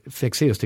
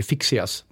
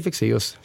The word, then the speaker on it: Fexeus: Det finns ju ingen från USA och England som kan uttala Fexeus till exempel, utan där, där är det Fixeus, Eller någonting liknande, jag har ingen aning om varför. – Men, men jag tycker Fexeus känns bra.